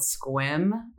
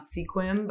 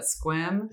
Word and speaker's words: Sequim: So up from a place called Squim. Not Sequim, but Squim. – Is it spelled Sequim? – It's spelled Sequim. – But it's Squim. – Squim. Okay. One sound.